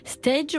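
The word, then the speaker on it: Steige: Monsieur Meister, qui est donc boulanger à Stage, on dit, c'est comme ça qu'on prononce, ouais, on est d'accord, on est dans une commune du Bas-Rhin.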